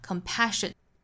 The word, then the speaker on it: compassion: 那这句话还有一个调和，就是在 compassion 这个 pa 的位置上，因为 compassion 这个词是这句话的最后一个重读的单词，那最后一个重读音节呢就在这个词的第二个音节上面， da da da compassion，所以后面半句这个调和的重读部分就在 pa 这个音节上。